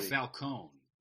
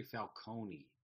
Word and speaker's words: Falcone: I always thought it was Carmine Falcone. And somebody on the set must have said, no, no, no, it's actually Falcone. You know, it's spelled Falcone.